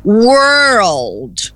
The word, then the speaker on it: world: Então, world, tá vendo? Dá a impressão de que são duas sílabas, world.